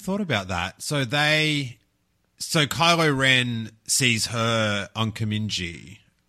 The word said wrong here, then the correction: Kijimi: Am I saying that wrong already? – Kijimi. – Kijimi. And then so Rey was seeing him on the Star Destroyer.